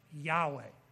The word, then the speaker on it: Yahweh: Many Greek transcriptions also indicated that YHWH should be pronounced Yahweh.